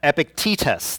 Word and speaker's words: Epictetus: The ancient Greek philosopher Epictetus, I don't think I said that right, Epictetus,